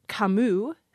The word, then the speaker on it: Camus: We're not getting completely consistent advice, and it gets complicated because you take, for example, the French name Camus, C-A-M-U-S, and there the S isn't even pronounced.